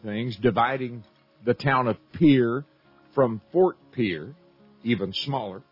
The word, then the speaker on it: Pierre: You look at a map, you might pronounce it Pierre. A lot of folks do. But around here, they pronounce it Pierre, South Dakota.